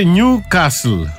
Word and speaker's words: Newcastle: Το Newcastle, ελληνιστή νέο καστρό, κατ' επέκταση και η ομάδα. Προφέρεται Newcastle. – Δεν έχει τάφ, έχει δίκιο. – Ναι, έχει δίκιο. Newcastle. Άνευ του.